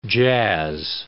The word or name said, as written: jazz